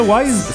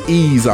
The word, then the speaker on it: Ys: J'ai jamais su comment le prononcer, c'est ça, Wise? – C'est Ease, en fait, qu'on devrait dire.